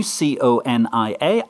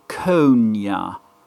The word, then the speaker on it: CONIA: I would call that C O N I A, On the grounds that if we don't call it C O N I A and pronounce it absolutely brutally with shorter space, then it sounds like something much, much, much worse than anything Ibor ever brought upon us.